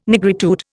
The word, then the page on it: nigritude: Indian